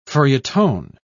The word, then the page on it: feuilleton: British English